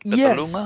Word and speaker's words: Petaluma: Is that the right way to say it? Petaluma? – Yes, you pronounced it correct, yes.